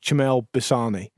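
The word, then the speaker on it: Chmel Blšany: And the team he started his career at, I think, I believe is pronounced Chmel Bissani.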